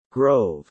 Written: grove

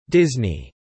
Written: Disney

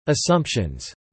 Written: assumptions

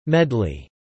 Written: medley

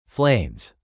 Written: flames

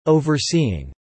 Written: overseeing

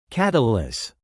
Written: catalyse

